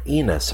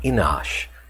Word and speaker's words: Enosh: In chapter 4, verse 26, this is very interesting that one is named here Enos or Enosh, depending on how you want to pronounce it.